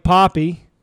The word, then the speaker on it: Poppe: Zach and old Blake Poppy, P-O-P-P-E, pronounced Poppy for you ladies' pleasure, they got stuck on the X every morning, so, you know.